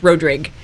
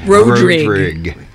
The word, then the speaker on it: Rodrigue: Kevin's last name is pronounced Rodrigue. Yeah. It's unusual. It's French Creole. It's very weird. – How did I not know this? – It's okay. – No, it's fine. – You're not alone. – Just a long O, yeah.